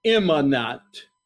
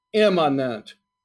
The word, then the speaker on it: Immanent: Immanent. Immanent. Let me spell it. I-M-M-A-N-E-N-T.